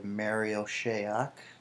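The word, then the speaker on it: Marial Shayok: At 54, we have Mario Šajak. Probably pronounced that wrong. He's going to the Sixers. – No, you got that right, and I'll chime in on him.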